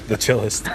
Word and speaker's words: Chthe'ilist: The chillest.